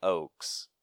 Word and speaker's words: Oakes: I'm pretty sure that's how you say it. O-A-K-E-S? Oakes? – Yep.